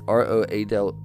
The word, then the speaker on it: Roald Dahl: The author Roald Dahl, if I said that right, it's Roald Dahl,